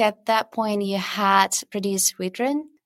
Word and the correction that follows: Wyrdren: yeah, so Wyrdren